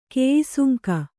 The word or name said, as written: ಕಾಯಸಂಗ